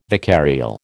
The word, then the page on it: vicarial: American English